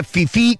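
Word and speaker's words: fifí: Eh, se dice fifi con acento en la última ahí, dice Karim.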